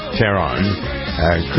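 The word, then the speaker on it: Taron: Is it, we pronounce it Taran or Taran? – Taran. – Taran. Okay.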